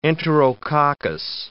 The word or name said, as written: enterococcus